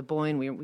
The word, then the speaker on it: Bóinne: Am I pronouncing this right? Bóinne, Bóinne? – That's correct.